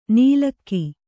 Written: ನೀಲಕ್ಕಿ

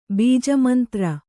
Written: ಬೀಜಮಂತ್ರ

